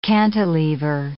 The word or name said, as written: cantilever